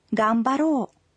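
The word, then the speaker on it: гамбаро (がんばろう): Но будьте осторожны, произносить нужно «гамбаро», хотя пишется это слово «га-м-ба-ро-ы».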